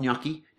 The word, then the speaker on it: gnocchi: And they're going to have Gandalf gnocchi. Now, that doesn't work for an alliteration point of view until you realise that gnocchi's got a silent G in it.